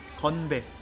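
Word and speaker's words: Konbe (건배): Konbe. – Konbe. – Konbe. – Konbe. – Yeah. – Like kanpai, yeah, or ganbe. Very similar.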